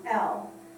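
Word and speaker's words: El: And in Isaiah 9:6, I told you that any time that the word talks about the Godhead, you will see E-L, which is pronounced L, and it means mighty.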